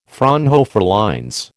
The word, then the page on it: Fraunhofer lines: American English